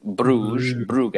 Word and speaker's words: Brugge: was playing Brugge. – No, you said it right. I butchered it.